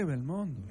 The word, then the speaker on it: Belmondo: Pero Belmondo, me equivoqué, Belmondo.